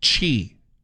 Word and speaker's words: Qi: She says that's pronounced chi, like cheese, chi. So QI is chi.